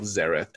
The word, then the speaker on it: Xaereth: which is spelled Xareth, X-A-E-R-E-T-H, or SoloBass15, S-O-L-O-B-A-S-S-1-5.